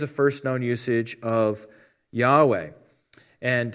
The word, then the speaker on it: Yahweh: Now that refers to, in the original Hebrew, God's name, which is commonly pronounced Yahweh.